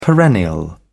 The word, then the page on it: perennial: British English